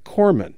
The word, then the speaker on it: corpsman: And before someone writes me about this, yes, Terry Gross did pronounce the word C-O-R-P-S-M-A-N on her show Wednesday as Corpsman. Her guest didn't correct her, but he did say Corman, which I would argue is the one to use. Though one source I saw offered Terry's pronunciation as an alternative.